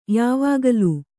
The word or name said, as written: ಯಾವಾಗಲೂ